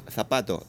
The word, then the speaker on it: zapato: The case is that in Spanish, in Castilian Spanish, anything with a Z, zorro Z, uh-huh. – is pronounced that way. Like, for instance, zapato.